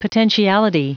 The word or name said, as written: potentiality